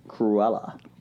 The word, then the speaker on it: Cruella: How was that pronounced? – Cruella. – Well, that was right.